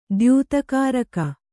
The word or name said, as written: ದ್ಯೂತಕಾರಕ